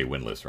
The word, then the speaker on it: Windlass: And Windlass? Windlass, like sailing, Windlass, or... like the sailing thing. Yeah. So, sir, is that how do you pronounce it? Is it Windlass? Do you actually say Windlass? – No, I was just exaggerating for spelling purposes. – Gotcha. Yeah, I think you just say Windlass, right?